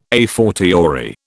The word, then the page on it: a fortiori: British English